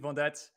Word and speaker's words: Vendette: So welcome to the show, Angelique Vendette.